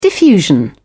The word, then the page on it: diffusion: British English